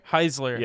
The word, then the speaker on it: Heisler: Because like, we had Gregory Holzer. Am I pronouncing his last name right? Like the photographer? – Gregory Heisler? – Heisler. – Yes, I'm sorry.